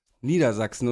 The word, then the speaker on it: Niedersachsen: Betonung, mit Betonung auf Sachsen, Niedersachsen. Und wenn ich nur das Bundesland sage, mache ich die Betonung auf Nieder.